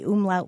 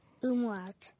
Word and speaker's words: umlaut: So the student who was studying German wasn't sure which letter the umlaut went on top of. – Are there any alternative pronunciations? – Not that I'm aware of. – Okay. Umlaut.